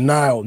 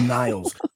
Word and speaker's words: Nile: I'm like, Nile, no S, like the river. I hate when people call me Niles.